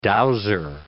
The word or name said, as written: dowser